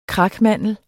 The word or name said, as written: krakmandel